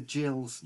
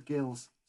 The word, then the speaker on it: Gills: He said, Cheltenham played Northampton in the playoffs and Gillingham's nickname is pronounced the Gills, not the Gills.